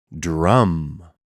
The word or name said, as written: Drum